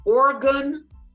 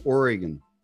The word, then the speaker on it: Oregon: But friend said it's pronounced as Oregon and Tillamook. Tillamook and Oregon. – I know Tillamook now. Set me straight on that.